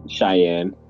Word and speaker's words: Cheyenne: Shane, Wyoming. Cheyenne, is it? Oh, God. – Cheyenne.